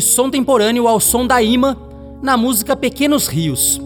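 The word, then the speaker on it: Yma: E para encerrar esta seleção do som temporâneo, só com novas caras da música brasileira, ouviremos Ima. Ima, que se escreve Y-M-A, é o nome artístico da Yasmin Mamédio, que em 2019 estreou com o álbum Par de Olhos.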